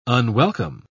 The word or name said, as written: unwelcome